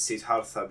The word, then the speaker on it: Siddhartha: This evening, we have gathered together an astute group of scholars to do a book review on Siddhartha, or I like to pronounce it Siddhartha because, of course, I am pretentious, by Hermann Hesse.